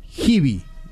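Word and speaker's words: Giby: Se escribe G de Guillermo, I de Indio, B corta de Víctor, Y de Yankee. Givi se pronuncia, pero se escribe así, Givi.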